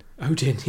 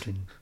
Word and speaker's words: Odin: Odin, yes. – Odin. You've got to say it like that as well.